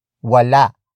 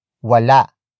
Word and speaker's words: wala: Wala kang kapatid ano? Wala kang kapatid, ano.